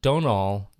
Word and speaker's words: Domhnall: And Donal does not pronounce the M. It's Donal Gleeson. Oh. There's no M. His first name is pronounced Donal with the M being silent.